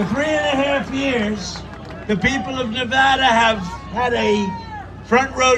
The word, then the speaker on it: Nevada: And you always have to say it correctly. Nevada.